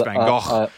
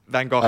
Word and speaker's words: Van Gogh: Because I can see so many, obviously I see your dad's work in your practice, but then I see like Van Gogh or Van Gogh. I think your dad shouted at me last time when I pronounced it wrong. And now whenever I say Van Gogh, I think of your dad telling me. – Yeah, it's Van Gogh. I love Van Gogh. – Van Gogh, that's it, you just said it right.